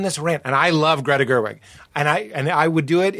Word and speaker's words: Gerwig: Did I get her name right? Greta Gerwig? Yeah. Greta Gerwig.